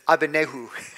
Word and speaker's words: Abednego: I heard the actual pronunciation is Abednego, but we just say Abednego, I guess.